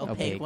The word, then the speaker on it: opaque: The opaque one. Am I saying that right? – Yeah. The opaque one.